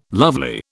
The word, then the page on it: lovely: British English